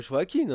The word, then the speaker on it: Joaquin: Oui, parce que c'est toujours toi qui dis Joachim au lieu de Joachim, je sais que t'aimes bien faire le malin là-dessus. – Bah, je sais pas euh, comment, comment le prononce, il dit pas euh, Joaquin.